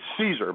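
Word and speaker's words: Szczur: Not Serger. That's not the right way to pronounce his name. The Villanova guy from Cape May that was the running back who made the Chicago Cubs, and he it's Caesar, Matt Caesar.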